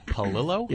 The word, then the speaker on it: Palillo: Palillo? – Yeah. – How do you spell that? – P-A-L... – Illo. – I-L-L-O.